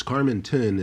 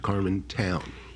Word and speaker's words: Carmenton: So it's Carmenton and not Carmentown.